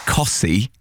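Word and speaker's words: Costessey: When I used to do work experience at BBC Norfolk, getting some of the texts and calling people back from places like Cossey, but it's spelt Cottesy, is...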